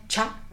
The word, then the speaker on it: Tscha: Se escribe T-S-C-H-A, pero se pronuncia Cha.